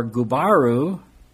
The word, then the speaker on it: Gubaru: Another possibility is that Darius is another name for Gubaru, kind of like Subaru. Subaru.